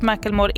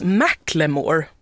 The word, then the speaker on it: Macklemore: Jag har hört någonstans att det uttalas Macklemore. – Det stavas så i alla fall, va? – Ja, men, att det är så jättevanligt att folk säger Macklemore. – Just men att han faktiskt typ uttalade Macklemore.